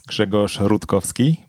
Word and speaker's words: Grzegorz Rutkowski: Okay, my full Polish name sounds Grzegorz Rutkowski, but it's quite hard to pronounce, so that's why I'm using Greg.